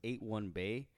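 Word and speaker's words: 81Bay: Because if you look at the logo, it's 81 Bay. That's how they say it. It's a little bit of word on play. It kind of looks like 813.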